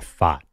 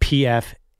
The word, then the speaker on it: Pfaadt: I have a good sponsorship for Brandon Pfaadt. It's spelled Pfaadt. If Travis Kelce stops doing the Pfizer ads, Pfaadt, PF for Pfizer.